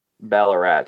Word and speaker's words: Ballarat: Ballarat.